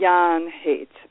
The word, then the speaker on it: Jahn Haight: Lisa Jahn Haight or Jahn Haight? Am I saying your name right, Lisa? – It's Jahn Haight.